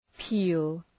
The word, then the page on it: peel: pi:l